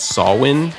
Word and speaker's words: Samhain: So Samhain, spelled S-A-M-H-A-I-N. Again, I don't know why it's pronounced Samhain. There's no W.